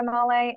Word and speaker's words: Male: And my last name is spelled M as in Mary, A-L-E. And then also I'm on Twitter at Victoria Male and Instagram at Victoria Male #1.